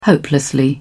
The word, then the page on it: hopelessly: British English